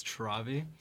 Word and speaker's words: Travi: It's a little bit like pronounced differently. It's Travi. – Travi.